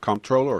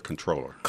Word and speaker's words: comptroller: Comptroller or controller? – Comptroller. – You know, pronunciations of English, it is called control.